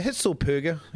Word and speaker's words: Hitzlsperger: Hitzlsperger, how do you say that? – Hitzlsperger. Look, he's only... Yeah, Hitzlsperger. Yeah, that's it, I think.